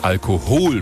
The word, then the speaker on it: Alkohol: So singt das Herbert Grönemeyer in seinem berühmten Song Alkohol mit Betonung auf dem zweiten O.